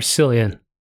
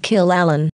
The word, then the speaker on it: Cillian: Is it Cillian or Cillian? – No, it's Cillian. – Oh no, I've been pronouncing it Kill Allen.